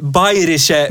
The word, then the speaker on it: Bayerische: I det här var det Bay, Bayerische Motorenwerke var den Bra gemensamma uttalet.